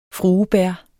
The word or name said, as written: fruebær